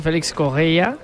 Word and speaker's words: Félix Correia: A pronunciarlo bien, Stassin, como nos han apuntado, y Félix Correia, como dice José, con muy buena pronunciación portuguesa.